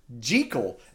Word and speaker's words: Jekyll: jekyll has been or sorry they actually call him Jekyll, and apparently uh, that's kind of how it's supposed to be pronounced, but it just got stuck Jekyll. So everywhere else is always called it Jekyll. But apparently, like the dude, uh, Robert Louis Stevenson, uh, when he wrote it, the dialect and the way you pronounce things in that town where he wrote it is because it's Jekyll. But yeah, it's Jekyll now.